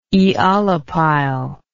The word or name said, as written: aeolipile